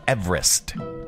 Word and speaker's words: Everest: The correct pronunciation of Mount Everest is Everest. It's named after Sir George Everest, a surveyor from Wales. I said Wales. And that's how his last name was pronounced.